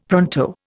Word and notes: pronto: Indian

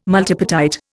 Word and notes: multipartite: Indian

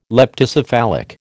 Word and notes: leptocephalic: American English

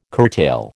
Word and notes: curtail: American English

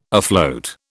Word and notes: afloat: British English

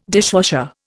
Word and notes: dishwasher: Indian

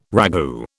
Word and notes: ragout: British English

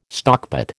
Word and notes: stockpot: American English

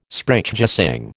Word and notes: Sprechgesang: American English

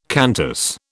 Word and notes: Qantas: British English

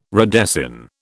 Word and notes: redesign: British English

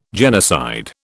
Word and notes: genocide: British English

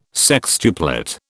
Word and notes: sextuplet: British English

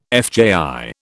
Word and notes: FJI: British English

